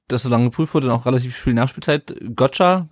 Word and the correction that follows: Gogia: wird er wahrscheinlich ausgesprochen, oder? Go nee, ja. ganz einfach Gogia.